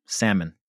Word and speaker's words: salmon: Salmon. – Salmon, not salmon. – Okay. – You don't pronounce the L. Some people say salmon. – You don't pronounce the L. It's salmon.